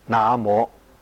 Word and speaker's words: 南无: 我们的语言是会随着时代变的，字的形状没有变，但是读音古今就不一样，所以应该要读古音，读成那摩。因为这个咒语一定要念当时的古音发音才正确。